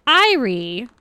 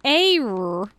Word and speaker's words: Eyre: The fact that we don't look at that name and go, Irie, Eyre.